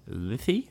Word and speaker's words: Lithe: How do you say this word? L-I-T-H-E. Lithy or Lith? – Lith. Just Lith.